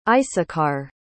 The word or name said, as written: issachar